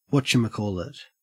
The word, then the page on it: whatchamacallit: Australian English